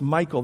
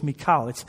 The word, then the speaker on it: Michal: I hear people saying Saul's daughter's name is Michal. That's not how you pronounce it. In Hebrew, it's Michal.